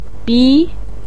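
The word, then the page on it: bi2: tone 2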